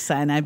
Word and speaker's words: Sinai: Is that how you pronounce that? – As people pronounce it differently, so I just go with whatever anybody says. I like Sinai because I'm Southern. I like the extra syllable.